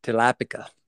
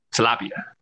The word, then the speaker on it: tilapia: Tilapica. Lots of fish. – Tilapia.